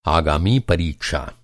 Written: आगामी

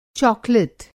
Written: चाकलेट

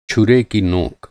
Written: छुरा

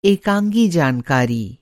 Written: एकाँगी

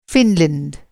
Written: फिनलैंड

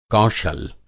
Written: कौशल

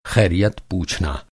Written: ख़ैरियत

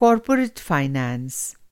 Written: फाइनेंस

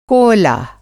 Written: कोअला

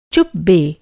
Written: चुप

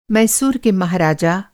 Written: महाराजा

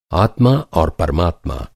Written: आत्मा

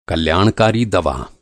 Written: कल्याणकारी

